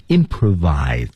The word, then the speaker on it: improvise: Improvise 是大家经常说的即兴表演，即席表演。 Improvise，注意发音，唇齿的配合。